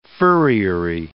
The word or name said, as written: furriery